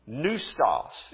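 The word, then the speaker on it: pneustos: Now, if you think about it, there are a few words in our English language that use that same combination of P-N-E-U and use just simply the sound of the N. Neustos is the way this one is pronounced.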